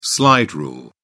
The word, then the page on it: slide rule: British English